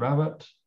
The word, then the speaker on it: Rabbitte: So first of all, Mr. Rabbitte, I think I'm pronouncing that correctly.